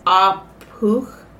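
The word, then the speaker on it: Ah Puch: I think you pronounce this as Apuch.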